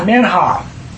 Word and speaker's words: minha: So we're going to look at the present, and that's the Hebrew word minha. – We spell it? – M-I-N-H-A.